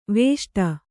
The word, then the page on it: ವಿಷ್ಟಿ: viṣṭi